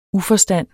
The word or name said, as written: uforstand